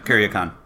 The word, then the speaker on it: Kuryakin: Is that how you pronounce it? – Yeah, Kuryakin.